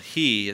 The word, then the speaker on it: He: He, even though it's spelled He, it's pronounced He, just to give you a little Hebrew lesson tonight.